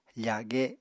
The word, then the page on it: fage: Apatani